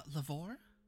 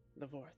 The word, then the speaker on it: Lavoie: Lavoie? I feel like I'm mispronouncing that. – Lavoie? – Lavoie, thank you.